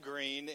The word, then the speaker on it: Green: Uh, now, there are two spellings for my last name, Green, in English. One is G R E E N, which is the way that we spell it. Uh, and then there's also G R E E N E.